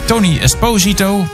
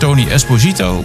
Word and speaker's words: Tony Esposito: Op 5, Tony Esposito. Of was het Tony Esposito? Maar net hoe je de klemtoon legt, Papa Chico.